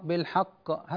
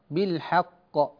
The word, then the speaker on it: بالحق: الحروف يا إخوة تتأثر بالمجاورة، إذا جاور مرقق مفخما أثر المفخم على المرقق فتخرج الحاء مفخما، نقول بالحق هذا خطأ، الصواب أن تقول بالحاء بالحاء ثم تنطق القاف بالحق هكذا.